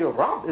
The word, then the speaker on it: Rab: Actually, Rab is really one syllable, isn't it? Rab. When you say the Rabun, it's two syllables when you pronounce the sign at the end of the letter, but it's actually Rab, one syllable letter, but so rich in meaning.